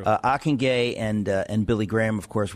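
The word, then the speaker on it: Ockenga: But of course, Ockengay, is that how you say it? – Ockengay. – Ockengay, and is that Dutch? – It is Dutch.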